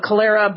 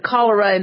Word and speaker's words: cholera: Certain illnesses like cholera. I used to call it cholera, but I know we pronounce it cholera in the States.